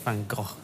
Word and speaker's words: Van Gogh: I think that's Van Gogh, actually.